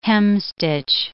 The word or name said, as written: hemstitch